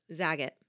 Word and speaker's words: Zagat: Zagat.